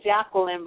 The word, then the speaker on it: Jacqueline: I say Jacqueline, but I like Jacqueline.